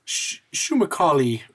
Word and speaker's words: Shumakali: Shumakali wanted me to come and say hello. – Shumakali? Who is that? – I think, did I get that wrong? – No, yeah, you said it right.